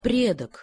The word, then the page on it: predok: Russian